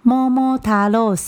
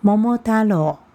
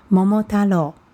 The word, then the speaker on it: 桃太郎: 好，在台湾很多人都念桃太郎さん。好，这句话呢，五个音呢，五个发音都没有错。但是呢，念成“ 桃太郎さん”就变成是台语的发音了。在我们日本的这个发音是叫做“ 桃太郎”，桃太郎。